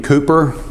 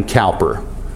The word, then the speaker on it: Cowper: How many of you have ever heard the name William Cooper? If you're of this mind, you might pronounce his name Cowper. C-O-W-P-E-R, and even the British have debates on how he pronounced his name.